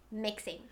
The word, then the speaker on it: 迷信: Mixing, yeah. – Mixing, because it's spelled like mixing.